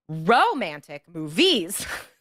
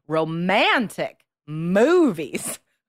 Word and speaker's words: romantic: You're stressing the wrong syllable. So to get a band 7 or higher, you need to correct. You need to stress the proper syllables and say romantic movies, for example.